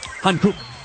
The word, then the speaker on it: Hankook: Hankook. That's how they say it.